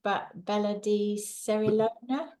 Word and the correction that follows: Cerignola: The Bella di Cerignola, yes. – Cerignola.